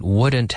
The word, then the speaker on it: would: People struggle with this pronunciation. So I think it's the third time this week I've practiced it. Would. It's the same as, like, madera.